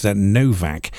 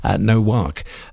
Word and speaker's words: Nowak: Now, there's a lot of people pronouncing his surname quite curiously as Novak. And I actually looked into this, and apparently it's a Polish surname, so it is actually pronounced Nowak, apparently.